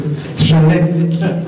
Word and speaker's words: Strzelecki: As a Polish ambassador, I will take the liberty to pronounce Polish names in a Polish way. So Strzelecki is the equivalent Polish original way of pronouncing Strzelecki, while Kościuszko is how we pronounce Kościuszko.